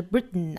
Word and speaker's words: Britain: 或者是 Britain 啊，